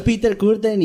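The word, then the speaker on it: Peter Kürten: Pero es Peter Curtin y Dusseldorf y ya la chingada.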